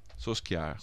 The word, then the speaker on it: Solskjaer: Solskjaer, Manchester United, yeah. – We say Solskjaer. – Soskiar. Babyface Killer.